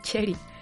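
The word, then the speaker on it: Cherie: Cherry, Cherry. – Cherry, Cherry Curry. Es que como tiene doble R el apellido, me confundo y pronuncio con doble R el nombre también.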